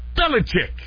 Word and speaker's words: Belichick: That's Bill Belichick.